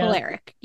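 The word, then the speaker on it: Kolarek: An old friend, Adam Kolarik? I always forget how to pronounce his last name. – I think Kolarik. – I apologize. Yeah. Kolarik, yes.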